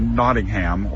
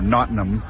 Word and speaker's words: Nottingham: And then we went up to a town called Calverton, which is just outside of Nottingham, or Nottingham, as I've been told. I pronounce it incorrectly.